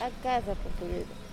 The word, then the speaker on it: A Casa Portuguesa: So we're outside the la casa portuguesa. Um, Portuguese. – A casa portuguesa. – I, sorry, I've, I've, been notified that I'm saying that wrong.